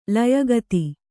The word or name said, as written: ಲಯಗತಿ